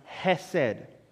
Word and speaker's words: hesed: It's a Hebrew word pronounced hesed, hesed love.